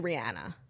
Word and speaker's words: Rihanna: Now, another thing that comes to mind is like, we've all been saying Rihanna's name wrong. It's actually Rihanna.